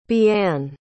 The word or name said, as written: bian